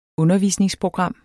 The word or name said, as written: undervisningsprogram